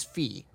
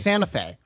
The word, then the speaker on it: Santa Fe: The thing I don't understand is when I, because it's like Shmev, when he said this before, he says S-A-N-T-A, Santa, and then it's just F-E, and he says Fee, right? – Yeah. – It's Santa Fe. – It's Santa Fe. – But yeah, it's pronounced Santa Fe.